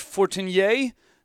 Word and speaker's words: Fortinier: This is Hank Fortinier. That is the French pronunciation of my name.